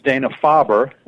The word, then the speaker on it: Dana-Farber: Yeah, the correct pronunciation is Dana-Farber, and Farber is named after a fine oncologist named Sidney Farber, who started the institution.